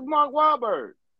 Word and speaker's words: Wahlberg: It's Berg. Mark Wahlberg. Like a wall with a berg. – Is that what I say, Mark Wahlberg? – No N. There's no N in his name.